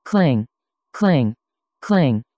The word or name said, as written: kling